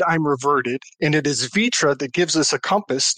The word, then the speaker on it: fitra: Am I saying that right? V-I-T-R-A? Or is it an F? – It has as F, not V. Vitra. So F-I-T-R-A.